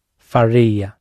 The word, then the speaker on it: faria: Faria. – Faria. – I said fa, didn't I? So it's faria. – The stress is on the e.